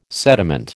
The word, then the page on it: sediment: American English